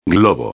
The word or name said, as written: globo